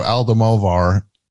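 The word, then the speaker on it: Almodóvar: Yeah, so he took a page out of Will Smith's acceptance speech of just like, Pedro Almodovar said, did I say that right? Yeah, I said it right.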